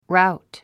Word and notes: rout: ráut; ラウトゥ

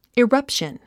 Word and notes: eruption: irʌ́pʃən; イラァプション

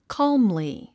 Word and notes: calmly: kɑ’ːmli; カァムリィ

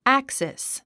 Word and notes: axis: ǽksis; アァクシス

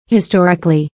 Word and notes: historically: histɔ́rik ə li; ヒストリカリィ

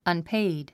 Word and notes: unpaid: ʌnpéid; アンペェイド

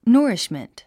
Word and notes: nourishment: nə́ːriʃmənt; ノーリッシュメント

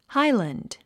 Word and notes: highland: hǽilənd; ハイランド

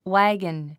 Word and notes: wagon: wǽgən; ワゴン

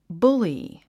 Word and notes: bully: búli; ブリィ

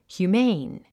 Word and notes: humane: hjuːméin; ヒューメイン

